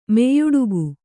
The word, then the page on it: ಮೆಯ್ಯುಡುಗು: meyyuḍugu